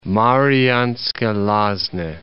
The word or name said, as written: Marianske Lazna